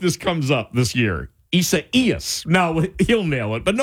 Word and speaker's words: Isaias: Some say Isaias. It's not that either. Here's how to say that name. Isaias. Isaias. The accent goes on the second E, which is the I, and you say Hurricane Isaias.